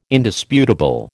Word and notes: Indisputable: American English

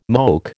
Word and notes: moke: American English

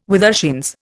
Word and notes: withershins: Indian

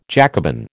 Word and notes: Jacobin: American English

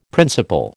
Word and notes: principle: American English

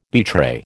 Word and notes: betray: American English

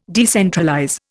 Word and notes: decentralize: Indian

